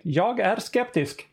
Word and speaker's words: Jag är skeptisk: t-shirt that is Jag Jag är skeptisk skeptisk. Is it how, how do you say that? Uh, that's perfect. Jag är skeptisk.